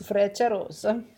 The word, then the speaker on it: Frecciarossa: Frecciarossa.